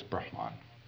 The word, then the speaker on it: Brahman: The highest caste is called the Brahmin, B-R-A-H-M-A-N, and that first A is a long A. Now that long A means related to, having to do with Brahman, with a short A.